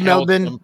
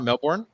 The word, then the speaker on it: Melbourne: It's Melbourne. It's pronounced Melbourne. – Is it really? – Yeah. – It's not Melbourne. – It's Melbourne.